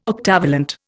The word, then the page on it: octavalent: Indian